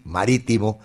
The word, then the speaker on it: Marítimo: A las 2 de la tarde, Mateus Uribe titular con el Porto ante Marítimo, se dice así, Marítimo, que tendrá Moisés Mosquera.